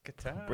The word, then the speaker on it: Qatar: The Americans say cutter, don't they? – Cutter. – Cutter, it's Qatar.